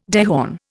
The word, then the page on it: dehorn: Indian